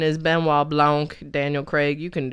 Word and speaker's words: Benoit: I know it does not look like it from the way that it is spelled, but his name is Benoit Blanc.